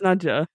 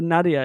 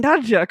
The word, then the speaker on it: Najia: And then eventually, I can't remember who, someone actually used the name but pronounced it wrong and called her Nadia. And she's still like, fucking Najia, come on, you can do this.